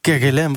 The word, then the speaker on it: Kerguelen: Nou, de nieuwe zuidoostelijke ezelspinguïn is ontdekt tussen populaties op de Kerguelen. Zeg ik dat goed? – Kerguelen, het is volgens mij Frans. – Oh, Kerguelen. – Dus Kerguelen.